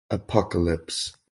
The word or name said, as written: Apocalypse